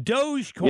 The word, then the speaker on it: Dogecoin: Is it Doggy Coin? What is it? Dogecoin? I don't know. – Doge. Dogecoin. – Dogecoin.